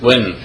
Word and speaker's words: Nguyen: How do you want to pronounce? – Just like win.